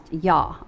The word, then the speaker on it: Iah: They're spelling it J-A-H. Egyptology, Yah, Jah, and I-A-H, which is also Yah. All of these are actually pronounced Yah, okay?